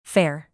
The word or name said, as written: fair